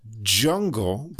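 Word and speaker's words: DJungle: So the first one, I believe, is pronounced Jungle, but that's capital D, capital J, UNGLE. So like DJ Uncle.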